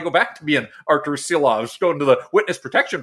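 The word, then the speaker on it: Šilovs: Uh, we've been saying it wrong, apparently, uh, for his time in the organization. It is Shilovs moving forward here now,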